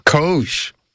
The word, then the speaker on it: COGE: How do you pronounce the acronym for this commission? – COGE. – COGE. – COGE. It is exactly what you think it is, Steve. And I know what's in the back of your mind when you ask that question. – Go on. What is in the back of my mind? It does rhyme with Doge.